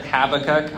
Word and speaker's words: Habakkuk: How do you pronounce the name? – Habakkuk. – You say Habakkuk? What do you say? – Rhymes with tobacco. – Right. So I've heard Habakkuk.